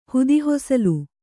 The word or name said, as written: ಹುದಿಹೊಸಲು